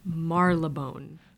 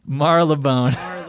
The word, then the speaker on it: Marylebone: How do you say that name? Marylebone? – Marylebone. – Marylebone. – Oh, I think it's Marylebone Station. Yes. – Marylebone. I think it's supposed to say Marylebone like that.